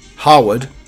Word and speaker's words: Harewood: It's a question of pronunciation, you see. As the decision to give up centuries old practice of speaking a name uh, in place without the E, Harwood, because it caused too much confusion.